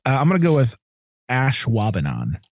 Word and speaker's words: Ashwaubenon: It's a Schwabenon. But okay. If you look at the pronunciation of it, they make it look like ash. We say the A a little bit shorter as Ashwaubenon.